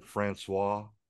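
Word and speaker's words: François: You like how I said Francois? – Francois. Francois, you need to roll your R's, Bubba.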